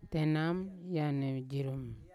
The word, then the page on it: tenam eyane jirum: Yawa